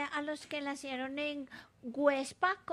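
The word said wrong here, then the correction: Güepsa: Perdón, Huebsa. Websa. ¿Ah? Con diéresis. – Websa.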